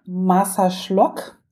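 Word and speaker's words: Marsaxlokk: Massaschlock. Das heißt, es wird ausgesprochen, äh, geschrieben Marsa also ein X, L-O-K-K.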